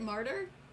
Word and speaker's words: martyr: I pronounced mater wrong. what do you mean you pronounced mater wrong? I thought, didn't. – Is that how you pronounce Is it martyr or mater? Martyr. – Uh, m A Y T. – Oh yeah, martyr.